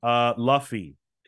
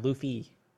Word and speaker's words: Luffy: It's not pronounced Luffy. – Oh. – Luffy? – Luffy? – Like the thing you wash your body with. – Yeah, Luffy. – A loofah. – Yeah. No, Luffy.